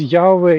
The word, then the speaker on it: Yahweh: Some people say Yahuwah, and stuff like that. I don't think it's correct. As far as I understand, it seems Yahweh is the most probable version.